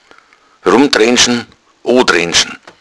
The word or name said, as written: drendschn